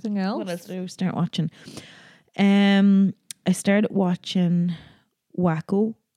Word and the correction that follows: Waco: No, it's not Wacko. Waco.